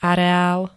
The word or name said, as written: areál